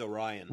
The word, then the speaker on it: Orion: I just know that O-R-I-O-N is always pronounced Orion. Oh. But dumb people who think that they remember things when they were three pronounce it Orion.